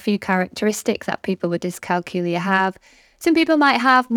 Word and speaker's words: dyscalculia: So it's dyscalculia.